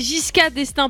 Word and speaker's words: Giscard d'Estaing: Ouais, c'est ce prénom, c'est Giscard d'Estaing. Alors, c'est un prénom Attends. – Qui. – Giscard ou JK? Parce que tout à l'heure, tu disais JK. – Non, c'est Giscard d'Estaing, pardon, excusez-moi.